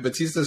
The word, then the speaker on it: Bautista: Batista is fine, but Saul, you can just call me Saul, man.